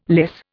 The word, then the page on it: lyse: Indian